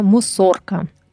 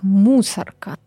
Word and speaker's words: Мусорка: Первое название – мусорка. Тут ударение именно на букву О, хотя, когда ты читаешь текст, можешь прочитать как мусорка, но это неправильно.